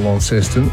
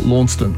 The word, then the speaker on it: Launceston: I just got actually an interesting email from Stephen who says the pronunciation of the Tasmanian city of Launceston is a good one. – In England, and I've been to this, actually near Cornwall, they have the original Launceston, and they call it Launceston. – Yes. – And here, some people say Launceston, and some people say Launceston. So we've got three different pronunciations.